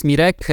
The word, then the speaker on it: Mirek: Okay, so yeah, my name is Mirek, Miroslav, depending on, you know, which way is easier to pronounce.